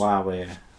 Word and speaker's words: Huawei: Huawei.